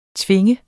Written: tvinge

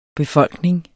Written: befolkning

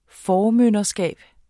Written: formynderskab

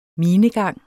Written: minegang

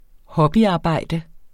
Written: hobbyarbejde

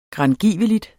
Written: grangiveligt

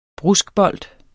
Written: bruskbold

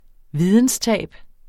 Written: videnstab

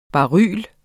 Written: baryler

